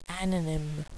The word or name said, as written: ananym